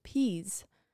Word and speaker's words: Pease: It says to pronounce it as peas, so I'm just gonna pronounce it as peas. P-E-A-S-E.